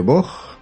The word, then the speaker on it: Vought: ¿De, de Vaugh, Bueno, V-O-U-G-H-T. Como se pronuncia en inglés.